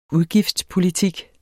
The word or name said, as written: udgiftspolitik